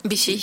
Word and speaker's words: Vichy: Vichy.